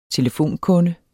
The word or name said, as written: telefonkunde